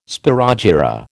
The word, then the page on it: spirogyra: American English